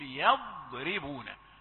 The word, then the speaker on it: يضربون: أنا قرأت يض، هذه الهل هذه الياء مفخمة، لا، فأقول يضر. طيب سمعت الراء هذه فيها شيء من التفخيم، يضربون، لا، أقول يضربون من غير تكلف ولا تعسف.